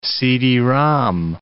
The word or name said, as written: CD-ROM